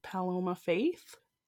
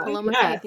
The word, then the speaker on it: Paloma Faith: I'm going to say this wrong. By Paloma Faith. – You got it right. – Yeah. – Paloma Faith, yeah.